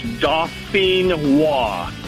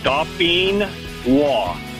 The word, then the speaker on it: Dauphinois: It's, like I said, it's French. It's Dauphinois. – Dauphinois.